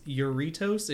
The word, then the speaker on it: Jarritos: Find a place that sells Yurritos. It's spelled Jarritos.